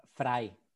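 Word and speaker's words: Freie: Pôsobíš ako uh, visiting researcher na uh, eh, Free univerzite, tak sa to vyslovuje správne? – Fraj, ako Fry.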